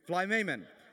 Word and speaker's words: Philemon: Philemon, I don't know if that's how you even pronounce this thing. How do you pronounce it? Do you know? Philemon.